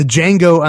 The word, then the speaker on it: Django: The Django Unchained, The D is Silent, 7.7 million.